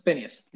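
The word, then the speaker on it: Finneas: I think that's how you say his name. – Phineas, yes, yeah.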